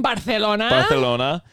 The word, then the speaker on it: Barcelona: I've been to Barcelona. Is that the correct way to say it? – Barcelona.